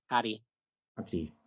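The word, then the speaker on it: Hati: Hattie, as it looks, but not Vanai. Hattie. – Pronounced ha. – Ha. Hattie. – Yeah. Hattie.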